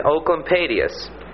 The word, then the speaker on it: Oecolampadius: It's spelled O-E-C-O-L-A. I spelled it wrong. O-E-C-O-L-A-M-P-A-D-I-U-S. And it's pronounced ooh, like O-O in hoot, you know.